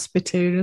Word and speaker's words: spittoon: And it is actually pronounced spittoon as well. – Is it? – It's not galoon. Spittoon. Spittoon.